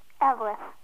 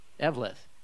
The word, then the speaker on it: Eveleth: How do you pronounce it? – Eveleth. – Eveleth. – Mm-hmm. – Just two syllables.